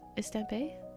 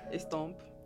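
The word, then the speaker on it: Estampes: Okay, how do you say this? Estampé? Estampé? Oh, you're so tired. – A little bit, yeah. Estomp. – Oh, okay. Estomp. Like, estampé. – I was like, yeah, that's completely wrong.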